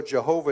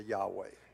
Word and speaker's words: Yahweh: So even though Jehovah is not how it was pronounced, the fact that it has the four consonants of God in it. – Aunque Jehová no es como se pronuncia en el hebreo cuando tenga esas cuatro letras, es el nombre de Jehová. – It is the name of God. – Es el nombre de Dios. – But if you want to pronounce it with the Hebrew vowels the proper way, it would be closer to Yahweh.